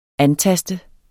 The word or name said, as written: antaste